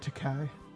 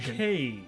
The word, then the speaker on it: Takei: Like Mr. Takai? – He's not Asian. He's Hawaiian. – He's very Asian. – Takei.